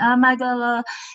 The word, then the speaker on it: Amygdala: Amygdala.